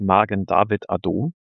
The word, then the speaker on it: Magen David Adom: Deutsch ausgesprochen heißt es Magen David Adom, mhm.